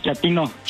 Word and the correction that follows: chatino: ¿Chapino? – No, chatino. – Es como chato, ¿verdad? Chatino. – Sí, azunero como chato, chatino.